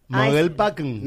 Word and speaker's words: Mogelpackung: En alemán se pronuncia así. Mogelpackung.